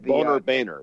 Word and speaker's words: Boehner: Well, Boehner is really how you pronounce it, I think. I don't know. But Boehner, yes.